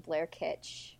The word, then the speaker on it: Blair Kitsch: You can find me on Twitter more easily at my name, Blair Kitsch, on Twitter. So that's B-L-A-I-R-K-I-T-S-C-H.